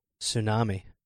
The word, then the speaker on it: tsunami: Tsunami. – Cool. I can't, I knew how to spell that. Close enough. – Silent tea.